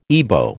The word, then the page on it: Ibo: American English